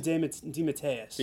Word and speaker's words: DeMatteis: DeMatteis? – J.M. DeMatteis.